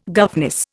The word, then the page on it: governess: Indian